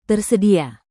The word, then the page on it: tersedia: Indonesian